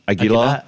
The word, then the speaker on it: Aguilar: Aguilar. – Well, okay, right. Okay. As it's spelt, really.